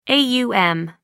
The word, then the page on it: aum: American English